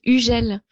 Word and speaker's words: Hugel: Yeah, the pronunciation is Hugel. You don't say the H and the G is like J. So Hugel.